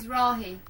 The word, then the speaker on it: Mizrahi: Mizrahi.